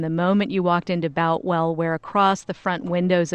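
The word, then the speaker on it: Boutwell: You know, Tony, we've heard several different ways of pronouncing it today. It's Boltwell.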